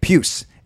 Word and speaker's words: Pius: That's how you say it, Pius. It's P-I-U-S, but it's not Pius like, you know, church and good morals. I'm sure, although I'm sure he might be. But it's Pius, like Pius, or don't say this, but like Pew. So that's how it's pronounced, Pius Suter.